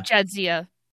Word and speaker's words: Jadzia: Jazita. Yeah, Jazita and Ezri Dax. Yeah, Jadzia.